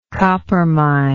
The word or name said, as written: coppermine